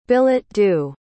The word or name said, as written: billet-doux